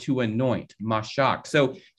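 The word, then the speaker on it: mashak (משח): This word anointed in Hebrew comes from a word in Hebrew that's pronounced mashak.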